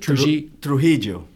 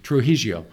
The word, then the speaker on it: Trujillo: So how do you pronounce it? – Trujillo. – Trujillo. – Yeah.